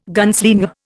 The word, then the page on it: gunslinger: Indian